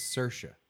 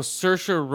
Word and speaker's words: Saoirse: It's Saoirse. – What? – That lady's name, Saoirse Ronan. – Who? Oh, Saoirse Ronan. Okay, for Brooklyn. – Yeah, I heard on the radio how to pronounce her name.